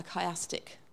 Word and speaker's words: chiastic: Okay, that's spelled C-H-I-A-S-T-I-C, chiastic.